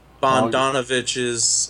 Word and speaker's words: Bogdanovich: It's Peter Donovich. Peter, how do you say his last name? Bogdanovich's first movie.